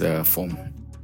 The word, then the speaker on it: Fom: I have a Berom name, of course, which is FOM, F-O-M.